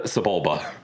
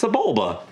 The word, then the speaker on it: Sebulba: Sebula. – Sebulba. – It's Sebula. – It's Sebulba. – Oh, okay. – They say it in the movie multiple times.